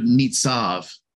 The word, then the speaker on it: nitzav: I love this word stands firm because it's the Hebrew word nitzav. Nitzav, and it's spelled Nun, Zadi, and Beit. In this case, the Beit has a V sound because Beit has two different sounds, a B and a V sound.